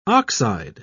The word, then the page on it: ox-eyed: British English